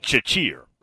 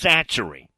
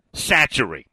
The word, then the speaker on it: Chachere: Yeah, Tony Satchery is spelt, the last name, C-H-A-C-H-E-R-E. So it looks like Chachir, if you just look at it. It's pronounced Satchery. Again, C-H-A-C-H-E-R-E is pronounced Satchery.